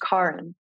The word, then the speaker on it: Karen: But my name is spelled like the traditional Karen. And it's pronounced Karen.